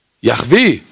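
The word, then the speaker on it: Jahwe: Yahweh, vermutlich spricht man es so aus. Man weiß es ja gar nicht so genau, wie man ihn eigentlich aussprechen muss.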